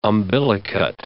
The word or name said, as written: umbilicate